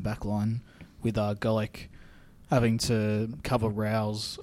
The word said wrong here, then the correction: Rolls: a lot, or Rolls, I should say, because that's how you say it.